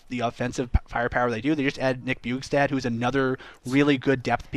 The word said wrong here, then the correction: Bjugstad: It's pronounced Bjugstad. – Bjugstad? – Yeah, come on. – Sorry, Nick Bjugstad.